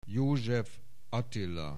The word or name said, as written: József, Attila